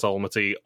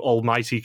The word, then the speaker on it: Almaty: Right before we actually get to Kazakh Film Studio, one thing I did have to find out is that D'Angelo does not know how to pronounce Almaty or Kazakhstan. – Kazakhstan. – Because in the travel agent, he does ask for two tickets to almighty Kazakhstan.